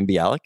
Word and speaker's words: Bialik: Maya Bialik.